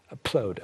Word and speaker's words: upload: 네, 그런데 영어에서는 up, P 발음을 살려줘야 됩니다. – Mm-hmm. – 그래서 upload 하면 못 알아들을 수도 있어요. – Yes, so you definitely need to upload. – Upload, okay? – Mm-hmm.